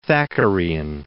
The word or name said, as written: Thackerayan